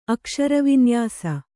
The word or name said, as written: ಅಕ್ಷರವಿನ್ಯಾಸ